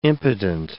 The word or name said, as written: impotent